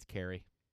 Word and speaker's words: Keri: I found out today that her name is spelled K-E-R-I. How would you pronounce that? – K-E-R-I. – Yes. – Kiri. – Kiri. Kiri. – Thank you. I've been calling her Kiri. Hey, Kiri. Good morning, Kiri. Since I got there, it's Carrie. – Like C-A-R-R-Y.